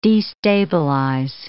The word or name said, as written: destabilize